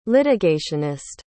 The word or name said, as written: litigationist